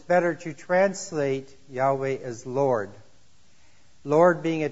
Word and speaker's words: Yahweh: Yahweh is what most scholars today feel is the correct pronunciation of the name.